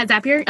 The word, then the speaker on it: Zapier: Zapier. – Oh yeah. Zapier.